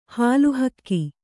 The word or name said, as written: ಹಾಲುಹಕ್ಕಿ